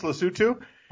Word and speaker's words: Lesotho: Interestingly enough, Lesotho, spelled Lesotho, but it's pronounced Lesotho.